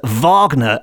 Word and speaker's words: Wagner: But when you say that aloud, I think most people, a lot of people, I certainly would pronounce Wagner the way it's supposed to be pronounced in the German, which is Wagner, as in Richard Wagner, which makes it into a V word, not a W word, which makes Wagner the Werewolf sound rubbish.